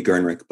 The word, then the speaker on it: Gernreich: And before we go any further, I just want to point out that it's Rudi Gernreich, not Gernreich.